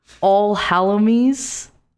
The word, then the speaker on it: Alholowmesse: It's All Hallowmas. A-L-H-O-L-O-W-M-E-S-S-E. And it means All Saints Day. – Wait. A-L-H-O. – L-O-W-M-E-S-S-E. – W-E-M-S-S-E. – W-M-E-S-S-E.